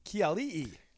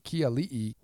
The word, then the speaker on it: Keali'i: Keali'i. – Keali'i, what a debut, man.